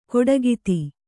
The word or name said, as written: ಕೊಡಗಿತಿ